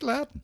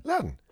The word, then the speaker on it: Loudon: How do you spell it? – L-O-U-D-O-N. – O-M? – N. – Oh, I think Louden. – Louden.